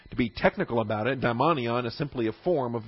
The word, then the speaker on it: daimonion: All right, vocabulary you're going to want to study under subpoint B includes this term daimonion. That's number 1140. Daimonion, number 1140. D-A-I-M-O-N-I-O-N, daimonion. And there is a similar term, not here, but elsewhere in the New Testament, called a daimon.